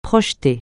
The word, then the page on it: projeter: francais